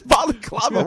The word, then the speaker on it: balaclava: What is balaclava? What is that? You put the balaclava on your face? Is that what that is? – It's basically balaclava. I think that's how you pronounce it.